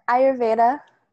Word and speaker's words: Ayurveda: And she is a yoga instructor, and you're going to have to correct me on how to pronounce this. Ayurvedic? – Ayurveda, yeah.